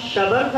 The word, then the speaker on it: شبر: حضرت ہارون کے بڑے بیٹے کا نام شبر تھا، چھوٹے بیٹے کا نام شبیر تھا، جو ہمارے محافل میں شبر اور شبیر ہو گئے ہیں، جبکہ یہ لفظ شبر نہیں ہے، شبیر نہیں ہے، یہ دو لفظ ہیں شبر اور شبیر، یہ عبرانی زبان کے الفاظ تھے شبر اور شبیر.